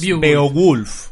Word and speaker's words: Beowulf: ¿cómo se dice? Beowulf. Beowulf. – Es Beowulf.